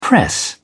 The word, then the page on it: press: British English